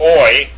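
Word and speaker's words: oi (οι): The next one is omicron iota, pronounced oi, as in oil, or the Greek form oikos, meaning house.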